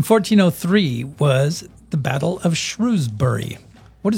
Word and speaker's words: Shrewsbury: It's spelled with an E. – I know. – Like a shrew, like the animal. – I know, but that's not how it's pronounced. – Shrewsbury. – Yes. – Okay. – Sort of rhymes with rosemary, kind of.